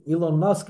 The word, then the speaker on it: Elon Musk: You're not, if we want to put it into more American terms, let's say the Elon Musk, is that how you pronounce the guy's name? – Yeah.